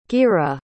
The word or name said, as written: geerah